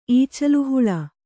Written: ಈಚಲುಹುಳು

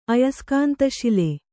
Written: ಅಯಸ್ಕಾಂತಶಿಲೆ

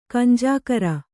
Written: ಕಂಜಾಕರ

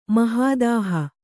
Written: ಮಹಾದಾಹ

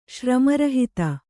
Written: ಶ್ರಮರಹಿತ

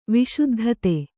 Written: ವಿಶುದ್ಧತೆ

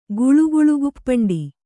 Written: ಗುಳುಗುಳುಗುಪ್ಪಂಡಿ

